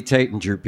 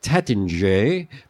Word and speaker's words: Taittinger: And for those of you who wonder if I'm pronouncing this correctly, I talked to the Taittinger people, and they said, yes, it was originally pronounced Taittinger, but Americans insisted on saying Taittinger, so it became Taittinger.